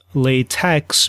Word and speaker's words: LaTeX: And so it's not pronounced LaTeX, but something like LaTeX, or actually LaTeX. And so now, apparently, based on the authors of this system, it's pronounced LaTeX.